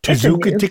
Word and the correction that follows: Tzatziki: It is a word, but shall I correct your pronunciation? – Really, this is why we get hate mail. – Tzatziki. – Yes. – Tzatziki.